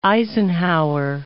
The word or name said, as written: Eisenhower